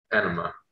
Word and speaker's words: Enema: And are we all good with Enemia? How do you say it? I'm sorry. – Enema. – Enema of the state.